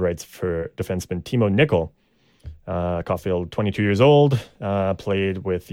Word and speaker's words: Caulfield: I'm assuming it's pronounced the same Caulfield. – As Caulfield, but it's spelled differently.